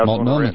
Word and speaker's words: Multnomah: How do you say that? Multnomah County? – Multnomah County. Yeah, Multnomah County.